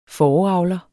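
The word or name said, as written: FÅREAVLER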